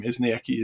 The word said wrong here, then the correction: Eskinazi: is it? Eskinazi. – Eskinazi.